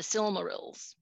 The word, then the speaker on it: Silmarils: Yeah, the Silmarils.